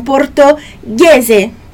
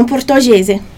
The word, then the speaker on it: portoghese: Sempre que você vê esse Hzinho ali entre o G e uma vogal, especialmente o E, você já sabe que a pronúncia é G e não é G, ou G, sei lá como poderíamos pronunciar isso. Mas é sempre o GH, tá bom? Então, portoghese, portoghese, não portoghese, ok?